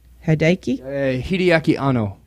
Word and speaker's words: Hideaki Anno: Hideaki Anno.